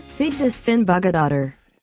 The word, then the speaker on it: Vigdís Finnbogadóttir: Vigdís Finnbogadóttir. – Vigdís Finnbogadóttir. – Ça, c'était du japonais. La bonne réponse, la bonne réponse était Vigdís Finnbogadóttir.